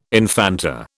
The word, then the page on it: infanta: British English